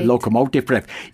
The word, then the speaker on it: Locomotive Breath: Locomotive Breath, habe ich es richtig gesagt? – Locomotive Breath, ja.